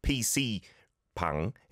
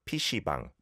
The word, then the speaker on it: PC방: This is a first, but the pronunciation is slightly, it's not PC pang, it's pishibang, pishibang. That's why someone said fishbang earlier. It kind of sounds like fish, doesn't it?